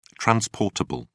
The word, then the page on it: transportable: British English